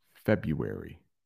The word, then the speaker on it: February: I can't remember, but that is a difficult one to spell in English because there is an R in the middle, but you don't really pronounce that R. So February. All right, Khalees.